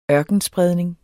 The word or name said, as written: ørkenspredning